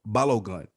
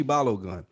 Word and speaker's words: Balogun: Please forgive me if I mispronounce his brother's name, Tunji Balung, or something of that nature. B-A-L-O, Balogun. Tunji Balogun.